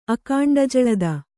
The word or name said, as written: ಅಕಾಂಡಜಳದ